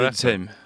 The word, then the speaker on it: Widerkäm: Där ryktas det om Paul Widerkäm från Halmstad. Uttalar jag Widerkäm. – Ja, Widerkäm, ja, precis.